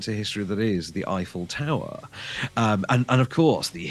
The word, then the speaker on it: Eiffel: I'm going to say it like trifle, Eiffel. – Iffel, by the Iffel.